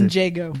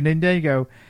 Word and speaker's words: Ninjago: Lega Ninjen. I can't even pronounce Ninjago. – It. Thank you, Ninjago.